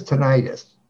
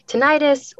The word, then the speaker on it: tinnitus: So from a clinical standpoint, what is tinnitus? – Yes. You might hear it called tinnitus or tinnitus. Either pronunciation is correct. Potato, potato.